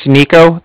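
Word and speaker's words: Niko: My name's Nico. That's N-I-K-O. And then I'm calling with the Bank of Cardiff, and that's spelled C-A-R-D-I-F-F, as in Frank, Frank.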